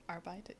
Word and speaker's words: Arbeiter: It would be more like an Arbeiter.